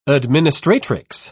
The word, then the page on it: administratrix: British English